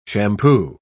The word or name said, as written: shampoo